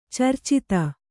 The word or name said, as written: ಚರ್ಚಿತ